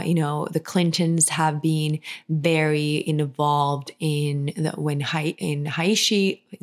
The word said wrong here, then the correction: Haiti: Is that how you pronounce it in English? When the Haishin, like she had this one person who was charged. – Haiti. – Haitian people. Haiti. – Yeah, sorry. Haiti.